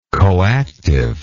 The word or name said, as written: coactivity